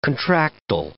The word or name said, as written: contractile